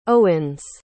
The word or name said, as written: owens